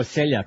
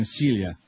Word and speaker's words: Celia: I'm not, I've been saying Celia. It was Celia Black, who was. And now there's Celia Cruz. I'm very sorry that I've been mispronouncing it all morning long.